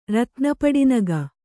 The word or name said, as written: ರತ್ನಪಡಿನಗ